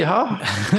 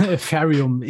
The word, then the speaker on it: Ethereum: Unser heutiges Thema Ethereum, ETA, Ethereum, wie sollen wir es aussprechen? E e ETH? – Ethereum, ich sage immer Ethereum.